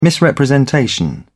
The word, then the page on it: misrepresentation: British English